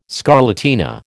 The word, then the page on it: scarlatina: American English